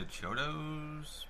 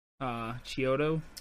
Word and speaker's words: Chiodo: Is it the Chiodos brothers? I always forget how to say their name. – Well, are you talking about the actors or? – Oh no, the directors. – Oh, the directors. Sorry. Chiodo. – Chiodo. That's how it is.